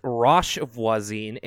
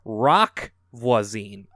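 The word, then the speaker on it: Roch Voisine: We were talking about a certain singer from Quebec, and she wanted to point out, she laughed a little, I pronounced his name as Roche Voisin instead of Rock Voisin. The H is silent.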